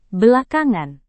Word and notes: belakangan: indonesian

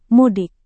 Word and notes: mudik: Indonesian

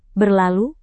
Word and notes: berlalu: Indonesian

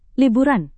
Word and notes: liburan: indonesian

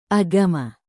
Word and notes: agama: indonesian

